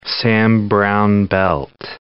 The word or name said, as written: Sam Browne belt